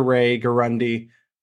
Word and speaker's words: Ray Guarendi: But once again, Dr. Ray Guarendi, did I say that right? – You did.